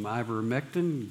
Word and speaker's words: ivermectin: I-V-E-R. – M-E-C. Am I not saying it right? Am I saying it wrong? – Yeah, you're saying it right. Ivermectin.